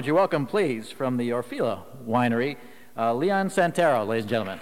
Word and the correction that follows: Santoro: Santoro. Santoro, Italian. I got your name wrong.